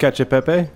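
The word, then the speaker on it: cacio e pepe: I often listen to the pod on my 45-minute walk home from the college where I teach, and his mention of Chicawe Pepe, is that how it's pronounced? – Cacio Pepe. – Cacio Pepe, okay.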